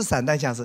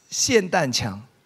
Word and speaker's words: 霰弹枪: 发音说错了，你们都不了解，不是散弹枪，是霰弹枪。